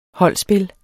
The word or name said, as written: holdspil